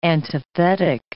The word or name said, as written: antithetic